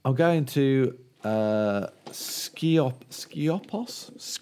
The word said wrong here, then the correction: Skiathos: Skiathos. – Oh, Skiathos.